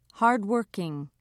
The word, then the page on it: hardworking: British English